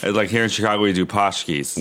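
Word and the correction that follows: pączki: Punchkis. They're pronounced punchkis, which is Polish, and that's just like a fatty donut filled with cream.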